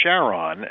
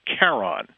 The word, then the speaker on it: Charon: Christie decided that he wanted to name it Charon,